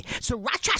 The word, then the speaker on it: sriracha: go uh, with sriracha sauce, sriracha, sriracha, sriracha, sriracha sauce. How do you say it? Sriracha. Sriracha, yeah, just like that.